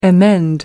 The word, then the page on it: emend: American English